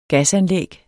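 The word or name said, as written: Gasanlæg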